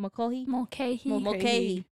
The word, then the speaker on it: Mulcahy: Mulcahy.